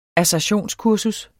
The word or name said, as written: assertionskursus